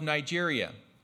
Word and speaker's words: Niger: But then we know of Nigeria. So as I looked it up, this up, it was interesting how it was. It's like, no, you don't pronounce it like the name Nigeria. You pronounce it Niger.